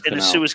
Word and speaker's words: Suez: Was the blockage in the Suez Canal. Yeah. I'm glad you said Suez then, because I was like, am I pronouncing this right or what? – The Suez.